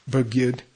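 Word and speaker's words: Bergyud (བརྒྱུད): Bergyud.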